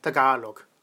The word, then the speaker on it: Tagalog: Sprache in den Philippinen, aber es das heißt, glaube ich, eigentlich Tagalog. Also Betonung auf der zweiten Silbe.